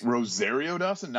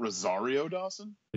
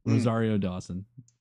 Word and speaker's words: Rosario Dawson: So Christina Hodson's already also written the thriller Unforgettable with Rosario Dawson and Katherine Heigl, which is... – Wait, Rosario Dawson, not Rosario Dawson? – Excuse me. Thank you. Rosario Dawson.